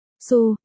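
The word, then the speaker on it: Su (苏): Hai, trong tiếng Trung, Tô đọc là Su, đọc lái ra là Su.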